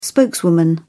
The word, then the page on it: spokeswoman: British English